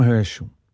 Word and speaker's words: Herschel: Herschel, actually. Let's just call him by his English pronunciation. William Herschel.